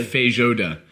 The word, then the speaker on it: Feijoada: It's pronounced almost exactly as it's written. – Oh. – Feijoada.